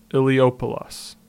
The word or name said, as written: Illiopolis